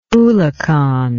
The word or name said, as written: oolachan